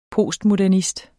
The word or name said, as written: postmodernist